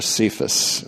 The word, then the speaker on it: Cephas: Paul almost always refers to him, except in a few occasions, as Kepha or Cephas, as we pronounce it. Cephas, which Aramaic is Kepha.